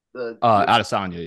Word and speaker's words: Adesanya: Adesanya, yep.